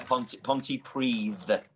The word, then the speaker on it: Pontypridd: Now, I want to make sure I pronounce this correctly. You were born and raised in Pontypridd, is that right? Just outside of Cardiff in Wales? – Yeah, Pontypridd.